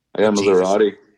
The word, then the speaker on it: Maserati: Maserati.